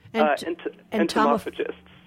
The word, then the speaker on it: entomophagists: Now, are you and your wife entomophagy? No, how do you say that? – Entomophagists. – Entomophagists, yes.